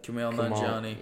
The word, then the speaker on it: Kumail Nanjiani: Uh, that guy, uh, Kumail Nanjiani.